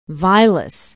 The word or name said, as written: vilas